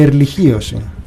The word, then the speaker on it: ερλιχίωση: Ερλιχίωση διαβάζεται, λέει, by the way.